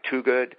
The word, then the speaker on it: Twogood: You can also Google me, Dr. Toogood. My name is spelled T-W-O-G-O-O-D.